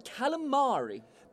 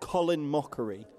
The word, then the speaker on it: calamari: To start, I'll have some of the calamari. – That's pronounced Colin Mockery.